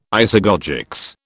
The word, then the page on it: isagogics: British English